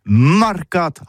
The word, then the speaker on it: marcat: Eh bien, d'ici à ça, tout ici, le mercat de l'Uni ou le mercat des rebelles, mais en gascogne toulousano, c'est prononcé sur les marcates, avec un A, marcat.